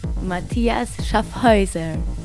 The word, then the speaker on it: Mathias Schaffhäuser: Matías Schaffhauser.